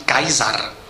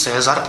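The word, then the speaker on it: Caesar: Gaius Julius César, původní výslovnost jeho jména má být Kaisar. Výslovnost César pochází až ze středověké latiny, se píše na internetové encyklopedii.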